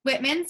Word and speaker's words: Whitman: Whitman.